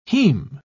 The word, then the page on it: haem: British English